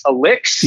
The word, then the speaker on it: Eliq: So it's Elix, yep. – E-L-I-Q.